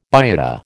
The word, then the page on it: biota: American English